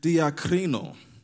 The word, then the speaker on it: diakrino: But if you actually look at this word, hesitation, or in some translations, debating or doubting, in the Greek, it is pronounced diakrino.